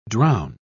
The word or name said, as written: drown